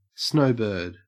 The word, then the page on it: snowbird: Australian English